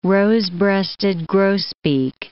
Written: rose-breasted grosbeak

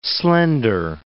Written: slender

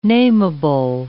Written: nameable

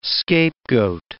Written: scapegoat

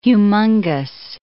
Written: humongous

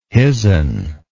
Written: hisn

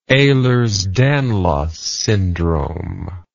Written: Ehlers-Danlos syndrome

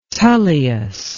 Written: Tullius

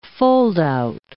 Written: foldout

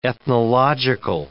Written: ethnological

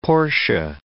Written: Portia

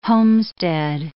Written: homestead

